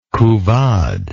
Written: couvade

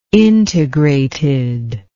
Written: integrated

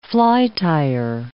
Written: flytier

